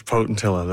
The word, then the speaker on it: potentilla: Are you familiar with the potentilla bush? Have I pronounced that correctly? – Yes, potentilla, that's right.